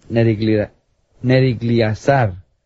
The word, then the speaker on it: Nergal-Sharezer: Pero allí reinó él por 2 años y luego fue asesinado por su cuñado, que se llamaba, en español no sé cómo se pronuncia, pero por el inglés es Nergal-Sharezer.